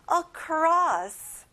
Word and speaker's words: across: 건너서, 건너편에 across. 친구들, 크로스가 아니라 across.